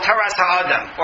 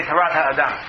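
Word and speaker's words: Tarat HaAdam (תורת האדם): The Ramban wrote a work called Taras HaAdam, or Tarat HaAdam, depending on how you want to pronounce it. The Ramban is from Spain, so I'll say Tarat HaAdam.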